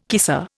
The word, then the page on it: kisser: Indian